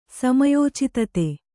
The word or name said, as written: ಸಮಯೋಚಿತತೆ